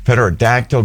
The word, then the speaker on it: pterodactyl: Because the P is silent. Pterodactyl, exactly.